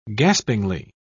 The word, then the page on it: gaspingly: British English